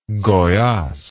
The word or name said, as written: goias